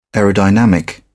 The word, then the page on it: aerodynamic: British English